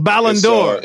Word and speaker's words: Ballon d'Or: Ballon d'Or. – That's the French pronunciation. It means golden ball.